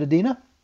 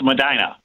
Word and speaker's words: Medina: Is it Edina? – I'm sorry. It's Medina. – Medina. Okay, we're missing the M on there.